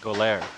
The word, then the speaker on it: Goler: Anyway, they're teaming up with, how do you pronounce this shoe store? – Goler. – Yeah. – Rhymes with go there. – 11 to 4, Saturday. They're announcing Style Your Soul, S-O-L-E.